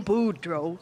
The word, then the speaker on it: Boudreaux: My name, Boudreaux. – Can you spell that for me? – So, my name, Boudreaux, because I am beautiful. The boo is for beautiful, and the dro, in case you didn't know, I was real.